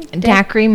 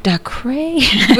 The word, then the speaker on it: Dacre: How did you say his name? – Dacre Montgomery. – Dacre Montgomery. – Ugh. – I was going, DeCray.